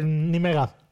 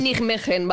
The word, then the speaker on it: Nijmegen: Se escribe ¿cómo se dice? – Nimega. Ya, es holandés. – Se escribe Nijmegen, ¿vale? – Nijmegen, Nimega, o sea, sí.